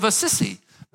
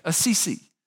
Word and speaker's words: Assisi: Most people say St. Francis of Assisi, but it's actually Assisi.